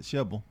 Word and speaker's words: Shelbyville: And, but you hear, but you hear people knew they call Shelbyville. – Shelbyville, yeah. – Yeah, but everybody knows it. Shovel. – Yeah. – You know, like shovel. Yeah, shovel. – Shovel. Mm-hmm. Just one syllable.